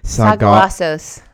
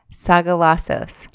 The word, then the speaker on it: Sagalassos: I don't really know how to say it. – Sagalassos. – Sagalassos. Say it again. – Sagalassos.